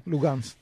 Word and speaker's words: Luhansk: Luhansk, ¿lo pronuncio bien? – Lugansk.